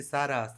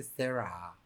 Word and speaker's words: Sarah: No se dice, no se dice Sara, se dice Sarah.